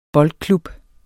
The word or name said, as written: boldklub